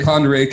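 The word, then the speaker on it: Pend Oreille: Just can't wait to hear gunshots in the background and finally like realize Pandorio. How do you say that? Pandorai? – Pandorai County is invading Stevens County in the leadership vacuum.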